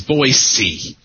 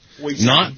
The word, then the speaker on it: Boise: Well, for one thing, they say the correct pronunciation is Boise. – Boise. – Not Boise like zebra. You pronounce it like an S because it is an S.